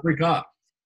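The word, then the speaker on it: Brikka: Bialetti, Brick. – No, Brick up. The Brick up.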